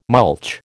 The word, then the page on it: mulch: American English